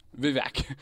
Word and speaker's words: Vivek: Vivek.